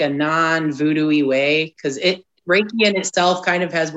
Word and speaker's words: Reiki: Is it Reiki, R-E-I-K-I? – Yep, you got it.